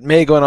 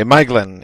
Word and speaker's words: Maeglin: But anyway, Maeglin is the correct pronunciation.